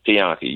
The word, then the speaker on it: Pianki: Well, the way it's spelled is Pianki. You was close.